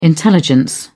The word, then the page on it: intelligence: British English